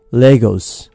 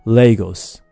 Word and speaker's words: Lagos: さあ今日は早速トピックの方に移っていきたいと思いますが、 Lagos wants to float。 – はい、 Lagos。これは 1991 年までアフリカ、ナイジェリアの首都だった町です。日本ではラゴスと発音しますね。 Lagos。